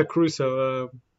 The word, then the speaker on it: Caruso: Is it Alex Caruso? Yeah, Caruso. For some of them, I was thinking Austin Reeves. But Alex Caruso, oh, took a drinker.